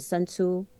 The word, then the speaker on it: Sun Tzu: And you know, the famous uh, general, Chinese general um, in English, they pronounce it Sun Tzu, S U N T Z U.